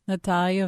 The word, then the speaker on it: Natalia: Natalia Veselnitskaya. – Oh, I didn't say it with the flair that you did for crying out. – Her name's not Natalie. It's Natalia. – Natalia Veselnitskaya.